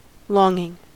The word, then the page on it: longing: American English